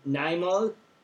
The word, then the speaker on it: Neymar: Neymar? Is that how you say it? – Yeah.